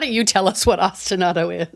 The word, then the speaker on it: ostinato: You have an essay on ostinato. Am I saying that right? Ostinato? – Yes.